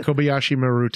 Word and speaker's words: Kobayashi Maru: It reminds me of what Kirk did when he, you know, rigged the Kobayashi Maru. Is that how you say it? – Yeah, the Kobayashi Maru test.